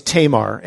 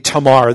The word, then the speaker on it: Tamar: And it begins by saying in verse 1, "Now Absalom, David's son, had a beautiful sister whose name was Tamar." And I'm not pronouncing that right. It would be Tamar. They don't really have that long A sound typically on names, but I'm probably going to say Tamar, so just excuse me.